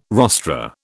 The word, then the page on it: rostra: British English